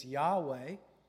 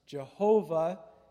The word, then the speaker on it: Yahweh: The more accurate pronunciation is Yahweh, because Jehovah takes the pointing from the word Adonai and applies it to the divine name.